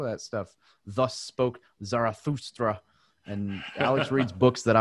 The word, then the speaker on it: Zarathustra: Zarathustra.